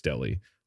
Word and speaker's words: Delhi: And I called it Delhi Watchco. It's Delly. Like the area.